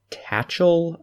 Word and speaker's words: Tatchell: He wrote it with Terry Tatchell, I believe is how her name is pronounced, and she has pretty much only worked with Neil Blomkamp.